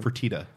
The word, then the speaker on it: Fertitta: Say it one more time. Fertitta. Fertitta. – Fertitta Fertata.